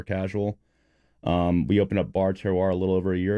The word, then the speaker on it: Terroir: Bar Terroir. A lot of people pronounce it Bar Terrier, which I'm totally fine with. Yeah, it's spelled T-E-R-R.